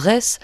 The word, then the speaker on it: Retz: qui commence par nous rappeler pourquoi on dit « Dent de Retz », ça s'écrit R-E-Z, et non pas « Dent de Ré ».